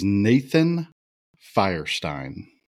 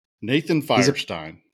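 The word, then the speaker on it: Nathan Feuerstein: His name And I don't know if you knew that this is how it's pronounced. His name is Nathan Feierstein. – Really? – Yeah. Also known as NF. Nathan Feierstein.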